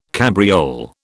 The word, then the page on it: cabriole: British English